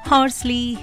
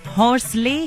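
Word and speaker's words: Horsley: अच्छा, वैसे आम बोलचाल में इस जगह को ना हॉर्सले हिल्स भी कह दिया जाता है, लेकिन अगर इंग्लिश में नाम की स्पेलिंग और कलेक्टर साहब के नाम पर गौर करें तो सही प्रोनंसिएशन होगा यानी सही उच्चारण होगा हॉर्सली।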